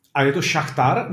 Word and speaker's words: Šachtar: Hele, ono to je vlastně jedno, protože ono záleží, jestli to vyslovuješ v ruštině nebo v ukrajinštině tak i šachtor, šachtar, takže záleží. A ukrajinsky jako to správně jak? Uh, šachtar. Šachtar. do šachtar Doněck.